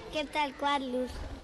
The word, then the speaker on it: Quetzalcoatlus: Quetzalcoatlus. – Quetzalcoatlus. ¿Lo he dicho bien? – Más o menos.